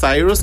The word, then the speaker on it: Cyrus: ¿Cómo se escribe tu Mi nombre es Cyrus, como Miley Cyrus, CyrusRUS.